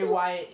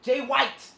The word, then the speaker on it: Jay White: But why do you keep calling him Wyatt? – Because that's how they say it. Jay White. Jay White. – His name is Jay White.